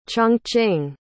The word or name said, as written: chongqing